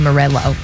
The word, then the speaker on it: Morello: When it has two L's, it looks like I should roll my L's. – Oh, yeah, you called him Tom Morello. Before. Morello.